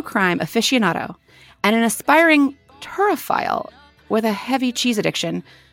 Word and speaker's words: turophile: First, did I pronounce turophile correctly? And second, what the hell does it mean? – Yes, you did.